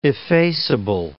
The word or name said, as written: effaceable